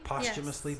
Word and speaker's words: posthumously: Posthumously? Is that how you pronounce that? Posthumous? I always thought it was posthumous. – Is it posthumously? – Posthumously. Yes. But that doesn't make any sense either, because that's not what TH, that's not the sound that TH makes.